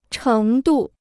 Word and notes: 程度: chéng dù

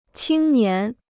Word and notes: 青年: qīng nián

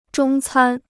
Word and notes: 中餐: zhōng cān

